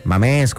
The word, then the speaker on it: Mamés: Mamés con acento en la E.